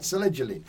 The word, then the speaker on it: selegiline: All right, selegiline. More emphasis on the E.